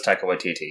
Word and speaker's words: Taika Waititi: Taika Waititi. I don't know how to say his name. – Oh, that was him. – Yeah. It was Taika Waititi, yes.